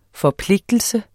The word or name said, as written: forpligtelse